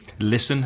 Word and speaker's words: listen: Listen has a silent T.